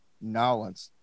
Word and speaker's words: New Orleans: How do you say it? – I always say NOLA, but you got Nolens. – Nolens. Nolens. – I say Nolens.